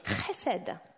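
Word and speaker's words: Chesed (חסד): Le mot pour la bonté, pour la miséricorde, c'est un mot hébreu qui est chesed. Chesed. J'espère que vous avez remarqué la jolie prononciation. Je suis même allée dans un dictionnaire audio pour avoir la bonne prononciation.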